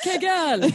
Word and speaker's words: Kegel: I say Kegel. – It's called Kegel. Kegel. – Yeah. It's pronounced Kegel.